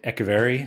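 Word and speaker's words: Echeverri: How good is this kid Echeverry? Is that how you say it? – Yeah.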